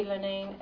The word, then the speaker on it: Linnane: Jackie Linane, L-I-N-N-A-N-E. Caroline Enright, E-N-R-I-G-H-T.